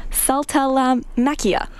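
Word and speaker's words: Saltalamacchia: Saltalamachica. Okay, wait, let me try again. I know there's still K at the end of that. Saltalamachia. – Saltalamachia. – Saltalamachia, yes.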